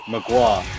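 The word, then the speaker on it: Mgła: My suggestion is Exercises in Futility 1 by Magua, and that's spelled MGLA.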